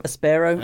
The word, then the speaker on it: Aspero: It's got an accent on the A, hasn't it? – Yeah. – But you got it. – Aspero.